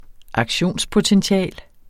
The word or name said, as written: aktionspotential